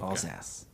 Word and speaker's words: Alsace: Alsace. It's spelled A-L-S-A-C-E, and it's a whole region. We're not from there, but I promise you, Alsace, that's how it's pronounced.